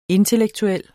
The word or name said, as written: intellektuel